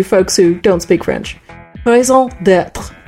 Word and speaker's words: raison d'être: I tried raisin de temps. How did you pronounce it again? – Raison d'être. – Raison d'être. – Let me pronounce that clearly. Yes. – R on the end of it.